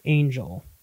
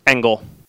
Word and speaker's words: Engel: Well, okay, so is it pronounced Peter Engel or Peter Angel? – Engel. – That's what I thought,